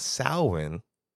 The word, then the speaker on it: Samhain: And in a later part of the movie, one of the characters says, Samhain. And I was like, I heard that before, All Hallows Eve. But then when I researched it, even though it's pronounced Samhain, it's spelled out Samhain, S-A-M-H-A-I-N, Samhain, which you had did that research, and I was like, oh, that's why the character's called Sam.